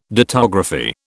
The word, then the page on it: dittography: British English